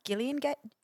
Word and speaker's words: Gillian Jacobs: You know it's pronounced Gillian Jacobs. – Gillian Jacobs.